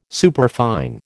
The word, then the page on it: superfine: American English